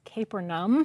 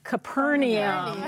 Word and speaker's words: Capernaum: And I am going to mention that I'm going to be pronouncing the name of the city as Capernaum, okay. but don't be confused if you have always pronounced it Capernaum.